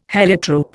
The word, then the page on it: heliotrope: Indian